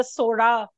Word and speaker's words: Chola: Listeners, by the way, just letting you know, some of us will be saying Chola because we can't do the Sora pronunciation properly. So you will hear Chola sometimes and Sora sometimes.